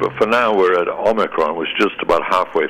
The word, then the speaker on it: Omicron: It's Omicron with a short O. It's not what TV and radio announcers keep hearing, and reporters too. They keep saying Omicron. That long O sound, O, is represented by an entirely different Greek letter, the probably much more familiar omega, which comes at the end of the Greek alphabet.